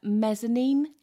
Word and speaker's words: mezzanine: It's on a mezzanine. Is that how you say it? – I can't believe you actually pronounced that right.